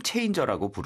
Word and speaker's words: changer: 우리 뭐 외래어로도 game changer라고 부르죠. – 그래요? – 그것을 영어로 어떻게 할까요? – Game changer이고요. Changer 끝에 R 발음 힘을 주셔야 돼요. – 네. – Changer가 아니고 changer.